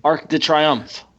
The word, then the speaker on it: Arc de Triomphe: Arc de Triomphe.